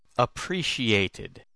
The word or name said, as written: appreciated